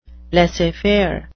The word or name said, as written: laissez-faire